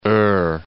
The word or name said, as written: Eure